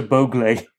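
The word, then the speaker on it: Bhogle: Much as I like your pronunciation, I think we should be clear, it's Harsha Bogle.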